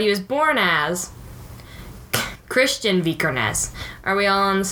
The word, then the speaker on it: Vikernes: So it's about Varg Vikernes, and that's how I'm going to pronounce that word. Vikernes.